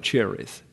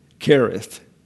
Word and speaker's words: Cherith: So the K here is to help you out on pronunciation, not call it Cherith. Okay, Brook Kereth.